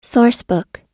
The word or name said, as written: sourcebook